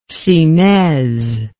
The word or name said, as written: cymaise